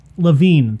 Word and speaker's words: Lavigne: Fuck, I can't say her name right. Lavigne, thank you.